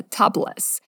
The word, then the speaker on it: topless: Topless, que em inglês pronuncia topless.